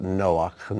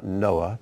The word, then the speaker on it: Noach: Notice I pronounce it Noach, not Noah, because it ends in a ch sound.